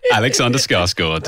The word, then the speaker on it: Alexander Skarsgård: Everybody, say hello to a very, very beautiful man, Alexander Skarsgård.